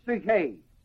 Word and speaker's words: psuche: The first word is the word psuche, spelled P-S-U-C-H-E, with a long E. Psuche, P-S-U-C-H, long E. The next word is the word nous, N-O-U-S.